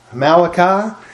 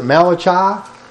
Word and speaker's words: Malachi: This is a book of Malachi, or if you don't like that, you can pronounce it Malachi. If you don't like that, you can pronounce it Malachi. Whatever you want to do.